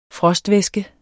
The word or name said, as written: frostvæske